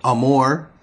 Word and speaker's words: Amour: Amber Amore, I think that's how you pronounce her name, is A-M-O-U-R. Amore. Amore.